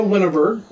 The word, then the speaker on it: Linaburg: So we have again Kara Linaburg.